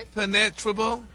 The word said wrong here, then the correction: impenetrable: No, your emphasis is on the wrong syllable. – They were so dumb, they had face masks. And a chin strap. – Impenetrable. – Impenetrable. – Yeah. – Impenetrable.